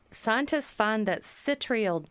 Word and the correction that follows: Citriodiol: C I T R I O D I O L.